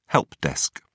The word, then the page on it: helpdesk: British English